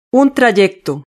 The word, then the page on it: un trayecto: Spain Spanish